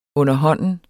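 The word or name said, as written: underhånden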